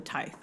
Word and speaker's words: tithe: How do you say it? – Tithe. – Tithe? – Tithe? T-I-T-H-E? Tithe? – I say tithe because I mean, it's like tithing in church.